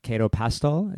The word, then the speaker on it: Cato Pastoll: I have Cato Pastoll. Is that the best way to say your name? – Yeah, that's right.